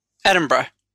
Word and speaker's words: Edinburgh: Sorry, how are you pronouncing the city you were from? – What is it, Edinburgh? – Close enough. – What would you say? – Edinburgh. Yeah.